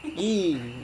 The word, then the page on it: i: Serewen